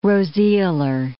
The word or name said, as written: roseolar